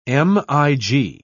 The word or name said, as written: mig